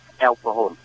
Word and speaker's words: El Cajon: Cái El Cajon này nó viết theo tiếng Mễ, nó chữ D nó đọc là Hôn, El Cajon.